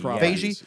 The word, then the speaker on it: Feige: But now that X-Men is in the realm of Marvel and is it Feige? Is that how they say it? – Feige.